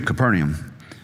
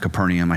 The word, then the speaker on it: Capernaum: Interestingly, so Mike pronounced it Capernaum. I hear other people say Capernaum, and I struggle with how to pronounce things. I struggle with pronunciation generally. So I have this software where you can go, and it tells you how to pronounce things. And I looked this one up, and it's Capernaum, which that just sounds pretentious.